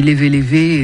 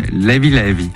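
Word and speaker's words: Leve Leve: DJ Tom B est notre invité pour la sortie du volume 2 de ses compilations sur Sao Tomé et Principe qui s'appelle, moi je dis Levé Levé et ça se prononce pas comme ça. Comment ils disent là-bas? – Levé Levé. – Levé Levé. Très bien.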